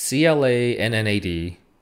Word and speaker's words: Clannad: I still can't properly pronounce this show. It's CLANNAD, Clannad.